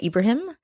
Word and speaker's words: Ebrahim: Can you properly tell me how to say your last name? I say it Ebrahim. Is it Ebrahim? – So I say Salimah Ebrahim. – Ebrahim. – Ebrahim. It's interesting about my last name. It's spelled E-B-R-A-H-I-M.